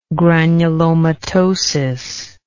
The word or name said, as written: granulomatosis